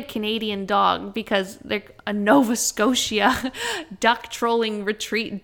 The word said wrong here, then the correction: tolling: Duck tolling or duck trolling? – Tolling.